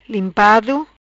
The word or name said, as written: Limpado